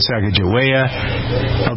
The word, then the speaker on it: Sacagawea: Sacagawea.